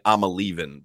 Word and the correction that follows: Iamaleava: but how do you pronounce his name, John? You probably know. – It's Nico Iamaleava.